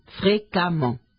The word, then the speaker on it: fréquemment: Pongan cuidado en la formación de los adverbios en ment, M-E-N-T, y sobre todo, cuidado con los adverbios terminados en A-N-T y E-N-T, de en et en, página 305. Abondant, abondamment. Y fréquent, que aunque se escribe fréquement, se pronuncia como si tuviera una A. Fréquemment, Pongan atención a esto.